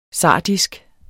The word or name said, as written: sardisk